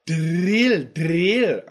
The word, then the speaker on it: drill: Now, this is a tough one for pronunciation, that DR sound. Drill, drill.